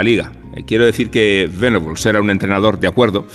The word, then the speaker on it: Venables: ahora me a Igual que se pronuncia vegetables y no vegetables, es más adecuado pronunciar venables que no venables. Aunque eh, buena parte del debate español se resolvió llamando a Terry Venables, tal cual se leía, venables.